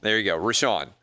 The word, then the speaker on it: Richaun: It would be interesting if Rishon is pronounced Rishon, given that it starts Rich. R-I-C-H-A-U-N. All right. So this guy. – It's Rishon.